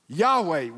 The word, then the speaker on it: Yahweh: But they went and stole two vowels from the name for God, for Lord Adonai, and now it's spelled Yahweh, Y-A-H, what, E-H, Yahweh.